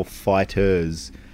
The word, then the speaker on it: FighterZ: The actual title for the Dragon Ball game is Dragon Ball FighterZ. It's not... – FighterZ. – Yeah, you're not supposed to pronounce the Z.